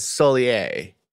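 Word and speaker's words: Sóller: Now, Bugs pronounces this town as Solier. Mm-hmm. But it's spelled S-O-L-L-E-R, and I just cannot figure it out. – Well, Solier, yeah, like...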